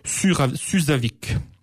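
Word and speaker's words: Súðavík: Orthographe pas garantie, enfin prononciation en tous les cas, parce que c'est écrit avec un décourbe, et il semblerait que d'après mes sources, que le décourbe se prononce comme un Z. Donc ça serait Suzavik.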